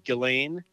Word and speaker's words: Ghislaine: And I don't know if that's been lifted, but I want to also talk to you about, is this the right pronunciation, Ghislaine? – It's actually Ghislaine.